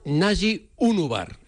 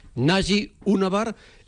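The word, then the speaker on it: Naci Ünüvar: Sí, el último fichaje confirmado se llama Nasi Unúbar. Así hay que pronunciarlo. El Espanyol incluso envió un sonido con el propio jugador para pronunciarlo. Nasi Unúbar, extremo izquierdo neerlandés de origen turco, procede del Ajax, tiene 21 años, jovencito, y es internacional sub-21 por Turquía.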